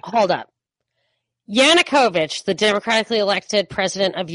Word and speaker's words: Yanukovych: Yanukovych. – Yanukovych.